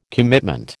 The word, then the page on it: commitment: American English